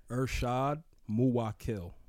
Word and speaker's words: Irshaad Muwwakkil: Irshaad Muwwakkil.